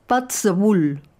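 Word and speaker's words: Puirt à beul: La misma idea, pero en gaélico, una lengua coexistente con el inglés en Escocia, Pats a Bull, que se escribe Puit a Beul, melodías de boca.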